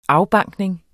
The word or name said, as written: afbankning